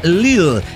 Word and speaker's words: Lidl: El pirata se refirió a la cadena alemana de supermercados Lidl como Lidl, pero se pronuncia Lidl. O por lo menos así lo escucho yo en los comerciales aquí en Suecia.